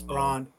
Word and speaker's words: Braun: Christian Brown, I don't know if it's Braun or Brown, I don't know, because it's spelled like Braun. But I think it's Braun.